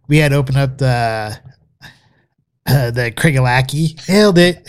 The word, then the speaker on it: Craigellachie: Craigellachie is the way you really pronounce it.